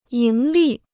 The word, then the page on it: 盈利: yíng lì